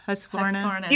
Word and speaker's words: Husqvarna: Husqvarna. – You did it. It's husk like corn, varn like varnish.